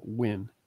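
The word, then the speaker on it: Nguyen: And this was an you pronounced that the director and writer's name Wynn. I knew it was something. – NG is a what? – Yeah, it's pronounced like W-I-N or W-E-N.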